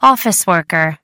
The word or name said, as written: office worker